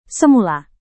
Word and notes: semula: Indonesian

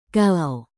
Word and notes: kalau: Indonesian

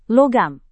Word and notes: logam: indonesian

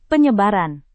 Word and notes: penyebaran: Indonesian

